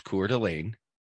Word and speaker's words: Coeur d'Alene: So for those of you, okay, so for those of you who need a little pronunciation lesson, it's pronounced Coeur d'Alene, right? Bless That's you. how you say it. And it looks like Queer Dialine.